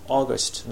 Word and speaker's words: August: まず 1 番の答えは During the first week of August、こう一番引っかかるところは最後の of August の部分ですね。これが 1 語で聞こえるという方がいらっしゃいました。これは一つはおそらく August の発音がですね、実際とちょっと違って聞こえるからだと思うんですね。この August の AU の音は A という音で、これ日本語の O をまあ伸ばすというカタカナで書くとそうなんですけども、日本語の O よりももうちょっとこう喉の奥の方でこう顎を引く感じで発音発話するんですね。